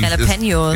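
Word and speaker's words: Jalapeños: Und Jalapenos, wie wird das immer richtig aussprechen? – Jalapenos.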